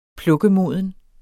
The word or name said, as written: plukkemoden